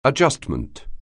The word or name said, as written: adjustment